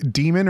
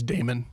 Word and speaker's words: Daemon: I don't know how to pronounce this. It's Demon or Damon? – D-A-E-M-O-N? – Yeah. – It's Damon.